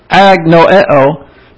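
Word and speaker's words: agnoeo (ἀγνοέω): You have the A in agnostic, and you pronounce it agnoeo, but actually it's noeo with the negative alpha.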